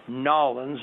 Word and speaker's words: New Orleans: Number six is New Orleans, or Nolens, as they pronounce it down there.